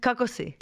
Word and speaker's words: Kako si: Kako si.